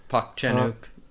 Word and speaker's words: Park Chan-wook: Park Chan-wook. – Park Chan-wook.